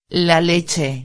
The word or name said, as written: la leche